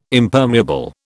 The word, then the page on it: Impermeable: British English